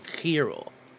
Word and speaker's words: gyro: The traditional Mediterranean pronunciation is gyro. Gyro.